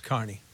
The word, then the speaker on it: Kearney: But for us, it's Carney. – Yeah. – No matter what. – But it's spelled Kearney. Carney would be C-A-R-N-E-Y.